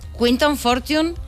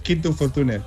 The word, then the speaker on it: Quinton Fortune: Quinton Fortune, ¿lo hemos pronunciado bien? – Sí. Bueno, aunque normalmente en España es Quinton Fortune, pero es igual.